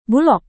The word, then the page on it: bulog: Indonesian